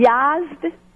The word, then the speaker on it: Yazd: I grew up in Yazd, Iran. It's spelled Y-A-Z-D.